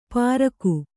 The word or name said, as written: ಪ್ರಾಕು